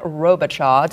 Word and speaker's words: Robichaux: We are joined by a special guest today, Mr. Chad Robichaux.